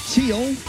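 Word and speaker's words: Thiel: Teal. – Yeah, that's how you pronounce Teal.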